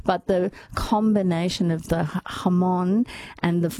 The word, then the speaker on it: jamón: And, it's not jamón. It's spelled jamón with a J, but it's jamón.